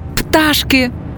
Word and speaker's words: пташки: Але якщо 2, 3, 4, то пташки. Наголосна А – перший склад.